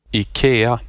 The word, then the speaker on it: IKEA: It's not IKEA, it's IKEA. Just, there you go. – IKEA. – Thank you, IKEA. There you go. I'm married to a Scandinavian. You were almost right. The letter I is pronounced like a long E. So it's IKEA.